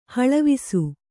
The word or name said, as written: ಹಳವಿಸು